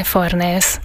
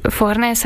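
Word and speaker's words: Fornés: A neve Maria Irene Farnes. Egy kubai származású drámaíróról van szó. A 60-as években kezdett írni, 60-as, 70-es, 80-as években alkotott. Maria Irene Farnesnek ejtik angolul Amerikában, mert hogy ő angolul írt, nem spanyolul.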